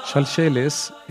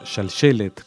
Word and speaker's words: Shalsheles: Shalsheles es la pronunciación ashkenazí del término hebreo shalshelet,